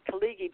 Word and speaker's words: Khaleghi: His name is Dr. Murdoch Kalighi, but I want to spell it for you. K-H-A-L-E-G-H-I.